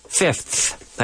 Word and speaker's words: fifth: It is important to be able to pronounce the ordinal numbers as well, like a fifth, fifth. Hay que sacar la lengua.